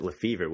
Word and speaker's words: Lefevre: But yeah, I pronounce it Lefevre, which much like you said, is kind of the boring, you know, English way to take a more exotic name.